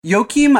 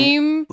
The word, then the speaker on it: Joachim: Joachim, I think, is probably right-ish. My inclination is always to, like, J it up a little bit more, which I feel like is wrong. – Joachim?